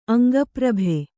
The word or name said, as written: ಅಂಗಪ್ರಭೆ